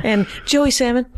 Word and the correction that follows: Slamon: Yeah, the L and the A are swapped. So people say Salmon all the time. – But it is Slamon. Spelled like salmon? S-A-L? – It's S-L-A.